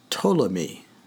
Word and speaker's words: Ptolemy: there's a guy named Ptolemy, or Ptolemaeus.